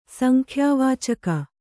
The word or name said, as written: ಸಂಖ್ಯಾವಾಚಕ